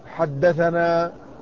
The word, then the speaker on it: حدثنا: فمثلا علماء الحديث إذا كتبوا يكتبون السند، فإذا كتبوا حدثنا يختصرونها بأن يحذف الحاء والدال ويترك لفظ ثنا، لكن لا ينطقونها ثنا، ومن الخطأ البين ما يحدث لدى كثير من الطلاب أنه إذا قرأ يقول ثنا فلان ثنا فلان، وهذا خطأ، فإن كلمة ثنا رسمت هكذا لكنها لا تكتب لا تنطق إلا حدثنا، وكذلك كلمة ثني تنطق حدثني، وكلمة أنا المراد بها أخبرنا.